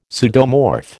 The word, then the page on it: pseudomorph: American English